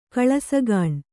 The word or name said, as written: ಕಳ್ಳಸಾಗಣೆ